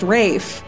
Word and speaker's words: Ralph: Well, it's Ralph, but it's pronounced Rafe.